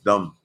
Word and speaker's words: dumb: Este, oh, dice la B en dumb es silenciosa. Ok, entonces dumb es dumb.